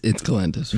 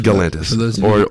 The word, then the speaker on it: Galantis: It's by Galantis or Jalantis, to each their own. – The cat. – But it's Galantis. – Galantis. Or Jalantis for those of you who know how to pronounce things correctly. – Or the weird cat. – I looked it up. It's Galantis.